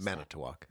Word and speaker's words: Manitowoc: Manitowoc.